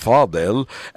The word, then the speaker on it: Faudel: And it was a song by a singer who's known as Fodel. And in Arabic, I guess it would be pronounced Fadil.